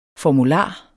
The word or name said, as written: formular